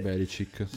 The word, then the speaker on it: Belichick: L'allenatore que- dei New England Patriots per, da, per, 20 anni insieme a, a, Tom Brady è stato questo Bill Belchick o Belichick, non so come si pronunci precisamente. – Belichick. Già sì.